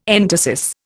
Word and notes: entasis: Indian

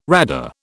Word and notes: RADA: British English

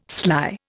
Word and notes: sly: Indian

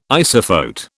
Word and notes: isophote: British English

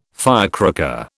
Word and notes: firecracker: British English

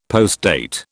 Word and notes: postdate: British English

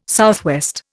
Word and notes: SW: Indian

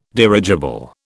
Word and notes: dirigible: British English

